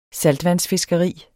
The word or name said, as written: saltvandsfiskeri